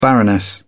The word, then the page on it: baroness: British English